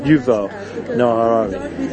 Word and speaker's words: Yuval Noah Harari: Your Yorav Noah Harari. There we go. I think I got it. Yuval Noah Harari. Yeah, got his name right.